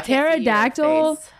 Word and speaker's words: pterodactyl: Pterodactyl is spelled P P-T-E-R. – Oh, what? – O-D-A-C-T-Y-L. – The English language is so stupid. What? – Chrissy's the only person that knows that pterodactyls start with a P.